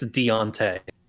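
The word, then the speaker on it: D'Onta: Is that how you say his name? – Yeah, it's Deontay. – There's no E in there.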